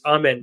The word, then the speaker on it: Amen: By the way, I know I've been pronouncing it wrong the whole time, and I'm gonna get better at it. Someone told me on Twitter that I've been pronouncing it wrong, and I think Jonathan corrected me on a podcast, and I still continued. So I know it's Amen. I believe it's Amen.